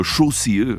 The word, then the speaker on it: José: Alors il est en effet de nationalité suédoise et là-bas on prononce son prénom quelque chose comme Chaussier.